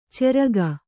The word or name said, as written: ಚೆರಗ